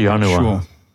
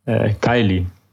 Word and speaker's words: Janua Coeli: I say Janua. Sure. Dutch pronunciation. – Could be. – But also, I mean, I learned, I would say Kylie, because that's how I learned it in high school. I went to a gymnasium and we learned Latin, but in the classical pronunciation.